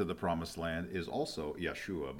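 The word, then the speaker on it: Yeshua (ישוע): Yeshua is how you pronounce it in Hebrew.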